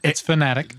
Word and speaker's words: Fnatic: It's Fnatic. – Just pronounce Fnatic.